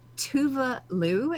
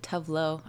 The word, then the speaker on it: Tove Lo: So Tuva Lu is how you pronounce her name, but it looks like T-O-V-E-L-O. – I've always said like Tuvlo. I haven't said it wrong all my life. – I googled it like 2 minutes ago. That's apparently how, because it's Scandinavian.